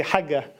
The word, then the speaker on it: Hajja: Actually, they would say Hajja. They don't pronounce the J.